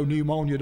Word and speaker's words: pneumonia: Do you know pneumonia don't start with an N? It starts with a P. And if you got pneumonia, what have you got?